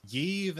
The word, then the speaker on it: Yves: And the lady that took the chip is Yves Adela Harlow. – Yeah. – It's more like, it's like starts with a Y, but you say it like Eve, which I guess I think she's a new character here.